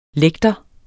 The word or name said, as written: lægter